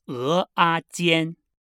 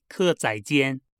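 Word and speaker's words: 蚵仔煎: This dish must be pronounced the Taiwanese way. 鹅啊煎. – 鹅啊煎. – 鹅啊煎. So please don't say 客仔煎, but read it as 鹅啊煎, even though the characters read 客仔煎.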